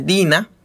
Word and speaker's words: DeNA: Entonces, eso inició bajo el nombre de una compañía japonesa que se llama Mobage, pero que ahora se llama Mobage Dina, que se escribe Dena